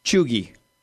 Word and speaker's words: cheugy: Chuggy. – Chuggy? – Chuggy. – Which is? – It's spelled C-H-E-U-G-Y.